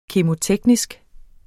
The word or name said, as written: kemo-